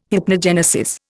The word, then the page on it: hypnogenesis: Indian